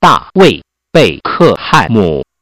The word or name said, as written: beckham